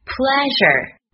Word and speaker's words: pleasure: 第三个 pleasure，那注意 pl 的发音是 pl，p 和 l，pl，ea 的发音是 e，s 的发音是 r，发音时舌端抬起，靠近齿龈后部，不要贴住，舌的两侧要贴住上齿的两侧，形成一条狭长的通道，气流通过时摩擦成音。